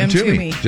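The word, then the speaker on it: Mtume: Is it M. Toomey? How do you say it? – M. Toomey. Just like that.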